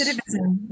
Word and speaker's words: recidivism: And it breeds a word I usually can't pronounce, recidivism. – Yes. Recidivism. – Yeah, thank yeah. you. Recidivism.